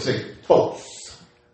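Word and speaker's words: say phos (φῶς): Spelled P-H-O-S. But pronounced, pronunciation spelled F-O-C-E. Say pronunciation spelled. Amen. It's actually spelled P-H-O-S. But when they break down the pronunciation of it, it's F-O-C-E, so you can know it's say phos.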